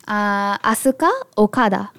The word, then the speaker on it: Asuka Okada: Asuka Okada.